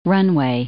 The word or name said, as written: runway